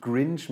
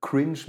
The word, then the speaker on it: Cringe: Es ist nicht Grinch mit G, sondern Cringe mit C, deswegen vorne das K hart ausgesprochen.